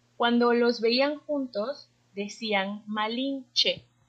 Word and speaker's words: Malinche: Entonces los, eh, los españoles no podían eh, pronunciar el sin, T-Z-I-N, y entonces lo pronunciaban che. So ahí está Malinche.